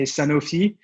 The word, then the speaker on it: Sanofi: It depends where you're at. When I'm back in France, we say Sanofi.